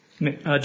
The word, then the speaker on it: Canada: Am I pronouncing the defendant's name properly? – Yeah, just like the country. – All right. Canada.